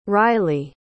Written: rylie

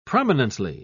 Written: prominently

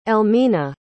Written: Elmina

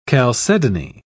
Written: chalcedony

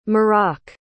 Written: marrock

